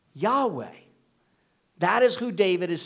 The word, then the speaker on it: Yahweh: Sometimes people will pronounce it Jehovah, but that does not seem to be in any way the way you'd pronounce the Hebrew text of scripture.